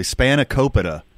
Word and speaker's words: spanakopita: Well, I'm glad you finally said that correctly. Spanakopita. – I've never heard that. – I've called it spanakopita like my whole life. And you know, you finally corrected me.